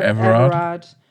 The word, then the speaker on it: Everard: I never know how to say her last name. – Sarah Everard.